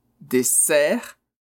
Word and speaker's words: dessert: Bon, il est fréquent qu'il y ait une erreur de prononciation entre désert et dessert, qui signifie sobremesa, dessert. Alors attention, le son z, désert, c'est le deserto. Et le son s, dessert, c'est-à-dire sobremesa.